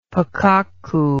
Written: pakokku

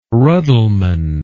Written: ruddleman

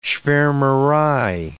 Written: schwarmerei

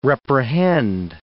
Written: reprehend

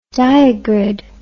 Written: diagrid

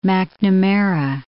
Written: McNamara

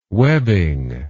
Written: webbing

